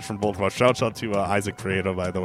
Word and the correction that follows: Prieto: And I know I pronounced that wrong. I got to roll that R. – Prieto.